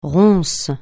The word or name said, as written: ronce